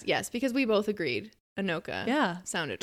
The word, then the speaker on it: Anoka: Like, is it Annika? Oh, yeah. – Shit. – Anoka. – No, it's Anoka.